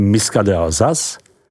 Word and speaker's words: Muscat d'Alsace: Silvánské zelené, Pinot Blanc, vyslovujeme Pinot Blanc, Muscat d'Alsace, vyslovujeme Miska d'Alsace, Pinot Gris, vyslovujeme Pinot Gris, Riesling, tato odrůda je nejvíce v Alsasku rozšířena.